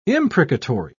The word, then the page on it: imprecatory: British English